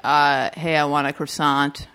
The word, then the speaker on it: croissant: That Beth Bear's character goes, "Oh, it's croissant, the T is silent."